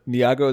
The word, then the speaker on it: Nyong'o: I love Lupita Nyong'o.